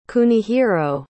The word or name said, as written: Kunihiro